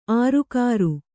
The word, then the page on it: ಆರುಕಾರು: ārukāru